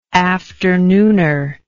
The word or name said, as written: afternooner